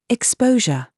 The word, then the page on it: GENRE: British English